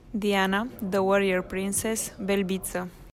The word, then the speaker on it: Belbita: Her last name is spelled B-E-L-B-I. T-A. So it looks a lot like Belbita on paper. Cody Merrow, of whom am I speaking? – Well, I feel like you said that. Now I'm going to say it incorrectly, but I think it's Diana Belbita.